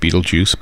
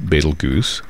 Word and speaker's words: Betelgeuse: Some people would, I think, like to pronounce that Betelgeuse, but I'm going to go with Betelgeuse.